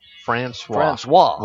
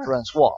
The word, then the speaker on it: Francois: And how do you pronounce his name? – Francois. – We were talking about a while ago, and we couldn't do it. – When you pronounce that name, it just makes you feel like you need to be drinking a glass of wine. – That's exactly right. – Francois. – That's it.